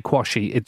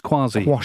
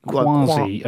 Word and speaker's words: Quashie: Please don't ever say Kwasi. It's Kwasi. – It's spelled Quashie. – Yeah, but it's pronounced Kwasi.